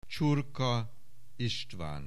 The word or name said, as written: Csurka, István